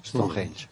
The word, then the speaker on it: Stonehenge: Pero mm, si me acaba de ocurrir una pregunta ah, eh, a, propósito de Stonehenge. Se pronuncia así, ¿no? – Stonehenge.